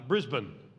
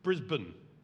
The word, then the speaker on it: Brisbane: Brisbane. Yeah, silent A. Brisbane. Take out the vowel. And you've got it.